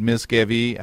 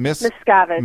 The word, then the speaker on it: Miscavige: Ron Hubbard, to its current leadership of David Miscavige. How do you pronounce his name? – Miscavige. – Miscavige.